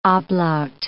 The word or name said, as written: ablaut